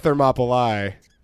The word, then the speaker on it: Thermopylae: In this next episode, we're going to talk the Battle of Thermopylae. – Thermopylae? Yes. – I have been saying it wrong. Mm-hmm. My whole life is a lie. – Yeah. So. – Thermopylae. – Yeah. – There we go.